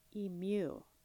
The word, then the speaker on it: emu: Emu. Oh, mew. I've been saying it wrong the whole time. – Emu. Emu. – Okay, it's an emu.